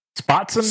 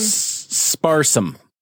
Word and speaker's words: sparsam: how do you say it? What's the thing? Spotsam? – Sparsam.